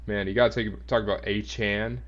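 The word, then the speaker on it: Achane: I guess that's how you say his name now. Yeah, I guess he corrected it because, you know, he had an interview, I'm sure, and he wanted to get it straight. So, yeah, it's A-chan.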